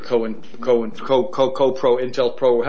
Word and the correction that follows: COINTELPRO: How do you pronounce that, Dave? – COINTELPRO, yep.